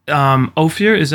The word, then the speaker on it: Ophir: You said in terms of like having to do like the 6 month preparation and things for the movie, did you, Ophir, am I pronouncing that right? – Yeah.